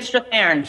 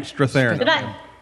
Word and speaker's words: Strathairn: David Strathairn. – Strathairn.